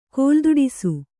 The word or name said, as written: ಕೋಲ್ದೊಡಿಸು